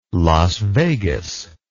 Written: Las Vegas

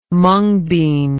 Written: mungbean

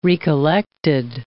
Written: recollected

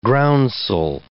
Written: groundsel

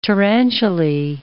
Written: tarantulae

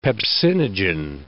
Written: pepsinogen

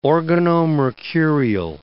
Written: organomercurial

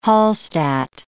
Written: Hallstatt

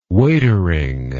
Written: waitering

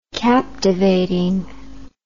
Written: captivatingly

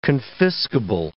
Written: confiscable

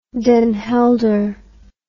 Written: Den Helder

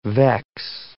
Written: vex

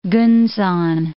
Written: Kunsan

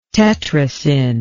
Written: Tetracyn

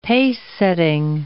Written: pacesetting

